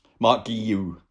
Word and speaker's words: Guiu: Marc Guiu. There's been lots of debate about how to say his name, basically, because essentially because it's a Catalan name, but you actually say it the same as you would in Spanish. But you've had lots of Spaniards looking at it going, this is Catalan, how do I say it? And completely mangling it, as I've just done as well.